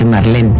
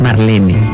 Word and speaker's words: Marlene: que todo el mundo dice Marlene, pero es Marlene.